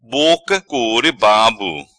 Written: Boca Core Babo

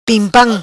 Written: Pimpam

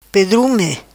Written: Pedrume

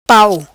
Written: Pau

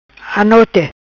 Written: Anote

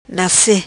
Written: Nace

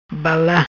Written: Bala

